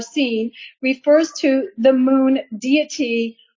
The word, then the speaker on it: Sin: And it says, okay, the sin in the wilderness of sin is merely a transliteration of the Hebrew word pronounced seen, S-E-E-N, which means thorn.